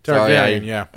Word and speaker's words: Targaryen: Daenerys Targaryen.